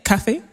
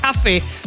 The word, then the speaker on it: Kaffee: Kaffee. Oh, is that how you say it? – Kaffee.